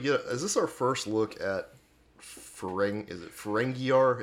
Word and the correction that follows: Ferenginar: Is that how you say it? – Ferenginar. – Ferenginar.